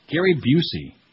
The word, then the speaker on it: Gary Busey: Gary Busey. Rhymes with you see.